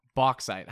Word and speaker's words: bauxite: Bauxite. How would you guys say that? B-A-U-X-I-T-E. – Bauxite. – I think that's how I've heard it said before.